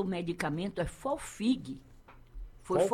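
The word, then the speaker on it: Folfigue: Folfig. – Folfig, o GUE é mudo, 200 Valeu.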